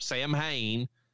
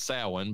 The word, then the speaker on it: Samhain: Samhain, which is not even how that's pronounced, Loomis. It's Samhain, but whatever.